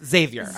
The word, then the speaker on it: Xavier: I did say his name wrong, and his publicist was like, it's Xavier.